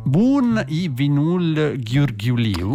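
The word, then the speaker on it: Bun e vinul ghiurghiuliu: Il titolo è Bun i Vinul Ghiurghiuliu. – Esattamente così, proprio la pronuncia è questa.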